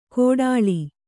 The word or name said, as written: ಘೋಟಾಳಿ